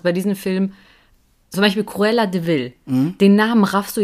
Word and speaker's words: Cruella de Vil: Deswegen nicht de, also klar, ausgesprochen Cruella de Vil. Aber es ist eigentlich Cruel Devil.